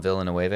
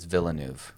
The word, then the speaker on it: Villeneuve: I used to call him Villeneuve. I didn't realize it's Villeneuve. – Villeneuve, yeah. Well, it's French. The French pronunciation.